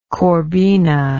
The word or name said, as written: corbina